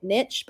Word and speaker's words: niche: I'm a big fan of just calling it niche, but that's probably because it rhymes with bitch.